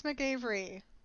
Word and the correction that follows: McAvoy: McAvoy? – It's not McAvery? – No.